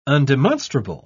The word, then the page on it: undemonstrable: British English